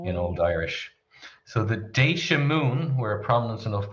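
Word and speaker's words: Déise: So in its modern form, it's spelled D-E-F-A-D-I-S-E, and it's pronounced Deisha. And I think this older spelling, D-E for the I-S-I, would be pronounced similarly.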